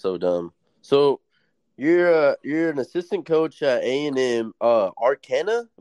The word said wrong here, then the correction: Texarkana: A&M Texarkana.